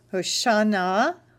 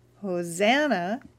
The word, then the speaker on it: Hoshana: I'm going to call this Hoshana, Hoshana Rabbah. Hoshana means in Hebrew, oh save, Hoshiana. It's an exclamation of adoration. Hosanna is the anglicized pronunciation in Judaism.